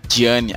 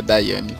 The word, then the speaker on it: Diane: E depois também temos da Daiane, assim que se pronuncia uhum. dela? É. – Diane, ah tá. Já que eu vejo assim, de, com desse jeito eu acabo pensando que é Daiane.